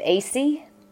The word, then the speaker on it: Aiesi: It's Detective AC. – AC. – AC. It's spelled A-I-E-S-I, and I'm with the Myrtle Beach Police Department here in South Carolina.